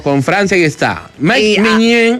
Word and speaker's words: Maignan: Maignan.